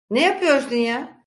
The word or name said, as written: ya